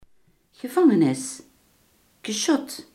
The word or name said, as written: gevangenis